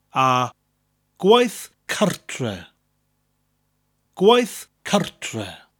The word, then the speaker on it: gwaith cartre: Gwaith, which has that slight G, that slight G sound at the beginning of the word, and a th, a TH sound at the end. Gwaith. Gwaith means work, followed by the word cartre. It almost sounds like two English words put together, cart and re, cartre.